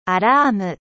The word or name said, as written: alarme